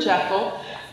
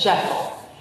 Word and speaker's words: Jekyll: Is it Jekyll or Jekyll? David Austin Jr. says Jekyll. Our marketing director says Jekyll. So there, you're both correct.